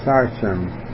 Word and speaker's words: Sichem: the father of Sichem. That's the way to pronounce it, S-I-C-H-E-M.